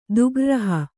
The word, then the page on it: ದುಗ್ರ್ರಹ: durgraha